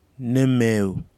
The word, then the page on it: nimeaw: kwamera